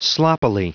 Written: sloppily

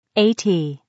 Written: 80

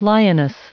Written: lioness

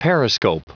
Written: periscope

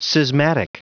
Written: schismatic

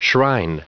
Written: shrine

